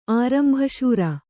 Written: ಆರಂಭಶೂರ

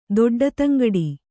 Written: ದೊಡ್ಡತಂಗಡಿ

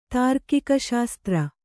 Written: ತಾರ್ಕಿಕಶಾಸ್ತ್ರ

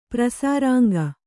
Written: ಪ್ರಸಾರಾಂಗ

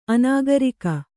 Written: ಅನಾಗರಿಕ

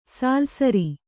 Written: ಸಾಲ್ಸರಿ

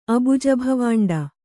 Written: ಅಬುಜಭವಾಂಡ

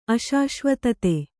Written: ಅಶಾಶ್ವತತೆ

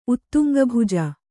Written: ಉತ್ತುಂಗಭುಜ

